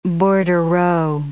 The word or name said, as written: bordereau